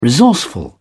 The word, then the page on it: resourceful: British English